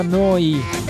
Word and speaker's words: Neu: Si scrive Neu, si pronuncia Noi, Noi, che vuol dire nuova, nuova emittente urbana.